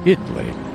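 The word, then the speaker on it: Italy: She drew the butterflies that floated, quiet like her, around Itley, Texas, spelled just like the country, but they pronounce it Itley.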